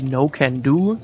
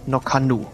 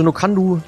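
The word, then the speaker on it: Nc'nean: Ich habe es hier und da mal ein bisschen komisch gehört, dass Leute das aussprechen wie No can do, so nach dem Motto, darfst du nicht, lustig, klingt so. Ist aber eigentlich Quatsch, weil die Brennerei überhaupt gar nicht so ausgesprochen wird, sondern die wird ganz einfach Nokandu ausgesprochen.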